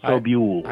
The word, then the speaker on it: Sobule: How do you pronounce her last name? – Jill Sobule. – Sobule. – Her name is pronounced Sobule.